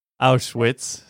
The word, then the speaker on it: Auschwitz: Auschwitz, no sé cómo pronunciar, pero ustedes entienden. – Auschwitz, sí.